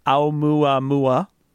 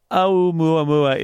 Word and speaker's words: Oumuamua: Oumuamua is, it's actually relatively easy to pronounce Hawaiian because there aren't that many sounds and they all sound like you'd expect. You just have to think about it a little bit.